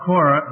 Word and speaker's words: Korah: Now, Korah, now, in the New Testament, it's spelled C with a C, as you remember, C-O-R-A. But this is the Old Testament spelling, K-O-R-A-H. Now, Korah, the son of Ishmar, the son of Kohath, the son of Levi.